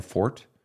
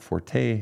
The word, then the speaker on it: forte: And yes, it is fort and not forte. It's not a French word. Its roots are Latin. And in Latin, you don't pronounce the E at the end of the word. So technically it's fort, not forte.